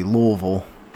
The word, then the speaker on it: Louisville: I'm I'm glad that you said it correctly, Louisville.